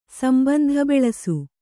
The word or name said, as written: ಸಂಬಂಧಬೆಳಸು